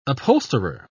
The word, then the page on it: upholsterer: British English